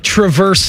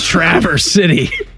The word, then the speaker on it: Traverse City: Like Traverse City is Traverse City. It's not Traverse.